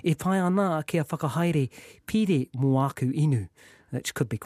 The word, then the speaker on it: pire: Pire is loan or account. So P-I-R-E, if you doubt my pronunciation, pire is a loan or account.